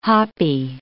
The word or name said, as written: hoppy